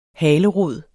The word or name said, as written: halerod